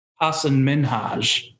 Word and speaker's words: Hasan Minhaj: Hasan Minhaj is how you say his name.